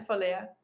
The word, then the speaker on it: Valea: Am I pronounce am I, am I pronouncing your name right? – Yeah, it's Valea.